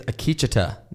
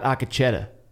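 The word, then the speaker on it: Akecheta: the guy whose name we have been mispronouncing the entire season. – Yeah, apparently it's Akichita. – Not Akichita. – Yeah, we botched that.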